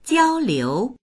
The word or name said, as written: jiao1liu2